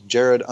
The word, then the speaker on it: Jerad: Uh, I think Jerad underscore Alexander.